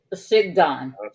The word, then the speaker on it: shigaon: In Deuteronomy, I have no idea how to pronounce this, Shigon? Mm-hmm. It's spelled S-H-I-G-A-O-N. – Shigdon.